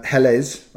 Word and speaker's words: Helles: I haven't been listening from the first episode, but the pronunciation of Hells is two syllables. No, that's just us. – Yeah, we just like to mispronounce everything. – Yeah, and to be honest, it just comes natural. – Yeah. – Helles, I think, is the way he's saying it is. – I always wondered if it was actually pronounced Helles, because of that second E.